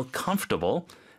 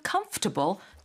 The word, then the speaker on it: comfortable: 이 comfortable 단어 자체가 원래 comfortable이잖아요. – 네. – 근데 발음은 comfortable 안 그러고 comfortable. – Comfortable. – Comfortable. – Comfortable. – 좀 약간 예외적인 발음이죠. – 네. – Comfortable. – Comfortable. – Yes. 그러니까 comfortable. 그래서 comfortable 맞나요? – 이 fort 발음이 없죠.